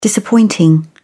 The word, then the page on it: disappointing: British English